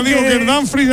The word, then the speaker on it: Dumfries: Lo digo bien, Miguelito, Dumfries. – Tengo dudas en esa ¿cómo es exactamente? – Yo creo que es Dumfries.